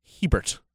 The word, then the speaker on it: Hebert: Would you pronounce my name? – Hebert. – Okay. At least you didn't say Herbert. That would have that's the only time when I'm like, if you're going to mispronounce it, you have to mispronounce it correctly. – To Bob Hebert. – There is no first R.